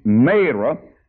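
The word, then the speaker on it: Marah: When they came to Mara, or Mara, some people call it Mara, but my Bible said it's a long A, Mara.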